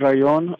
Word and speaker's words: Rayon: It's Rayon, R-A-Y-O-N.